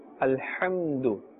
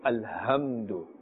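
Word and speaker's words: Alhamdu (الحمد): Alhamdu. Do not say alhamdu. Alhamdu, that is a different letter. Ha and ha. Ha is pronounced with a more heavy tone. And this is pronounced with a thinner tone.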